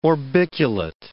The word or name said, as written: orbiculate